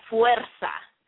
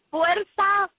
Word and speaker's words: Fuerza: It's called Fuerza. Fuerza, Fuerza, Fuerza.